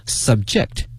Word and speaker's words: subject: 我们今天学一个词哈，这个词呢大家都会，但是呢用在今天的这个场景大家未必了解，这个词是呢 subject， subject，但是这个发音发生了变化。我们知道 subject 作为名词啊，它表示一个科目啊，一个项目，但是今天它呢词性发生变化了，它的发音也随之发生了变化，叫 subject，